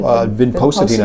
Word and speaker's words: Vinpocetin: Vinpocetin, am I pronouncing that correctly? – Vinpocetin, I generally say.